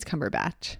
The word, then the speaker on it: Cumberbatch: the the new Grinch with Benedict Cumberpatch. – Yeah. – Is that how you say his name? I think so. Cumberbatch. – I think it's Cumberbatch.